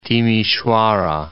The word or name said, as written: Timisoara